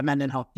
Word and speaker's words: Mendenhall: Yeah, Mendenhall.